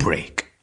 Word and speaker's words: brik: Yep, it's called brik and it's spelt B-R-I-K.